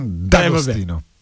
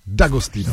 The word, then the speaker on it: D'Agostino: D'Agostino. – Jak? – D'Agostino. – D'Agostino, dobře.